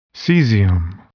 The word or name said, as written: cesium